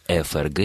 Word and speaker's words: ФРГ: Дело в том, что при такого рода аббревиатурах мы должны произносить буквы так, как они называются в алфавите. И действительно, с точки зрения правильной русской речи, мы должны говорить ФРГ и ФСБ, потому что название буквы звучит как F.